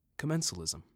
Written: Commensalism

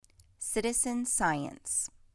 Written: Citizen science

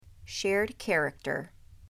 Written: Shared character

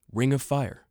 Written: Ring of Fire

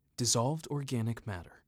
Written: Dissolved Organic Matter (DOM)

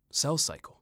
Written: Cell Cycle

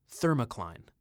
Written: Thermocline

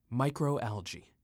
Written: Microalgae

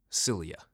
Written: Cilia

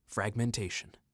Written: Fragmentation

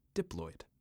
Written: Diploid

